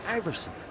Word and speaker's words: Aeverson: It is A-E-V-E-R-S-O-N, and it's pronounced Iverson.